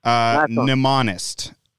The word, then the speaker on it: mnemonist: I believe it's mnemonist.